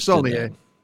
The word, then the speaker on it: Saulnier: Eric Brantstrom has selected 2015's Green Room, directed by Jeremy Saulnier. And actually, I don't know if it's pronounced that way or not. It's Saulnier.